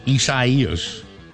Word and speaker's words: Isaias: Now, Isaias. Although a lot of people go, you're pronouncing it wrong. No, I'm not. That's what they said.